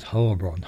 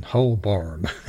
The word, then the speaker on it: Holborn: I love the way he says Holborn, Holborn. Probably the way it should be pronounced. We've probably been getting it wrong all these years.